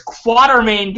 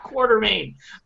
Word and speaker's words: Quatermain: It's Quatermain, guys. Nobody, and it's okay because nobody in the movies pronounces it right either. But yeah. it's Quatermain, not Quartermain.